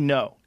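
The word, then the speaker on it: Ngo: Andy No.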